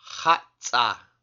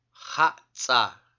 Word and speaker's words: Hatsa: The next letter is the letter X, and it makes the sound kh, the gargle H sound. And this sound appears in our word for bowl or eating basket. And the word is Hatsa. Hatsa.